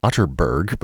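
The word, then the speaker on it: Atterberg: It's spelled Otterberg, but pronounced Otterberg. I have it on good authority.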